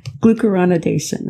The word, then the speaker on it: glucuronidation: Am I pronouncing that correct? – You've got it. Yeah, it took me a number of tries to get that down. glucuronidation. That's it.